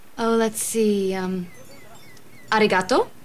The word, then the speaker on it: arigato: Like we say arigato.